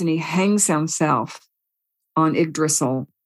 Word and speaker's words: Yggdrasil: But in the Norse myths, it's called Yggdrasil, and it's spelled Y-G-G-D-R-A-S-I-L, Yggdrasil.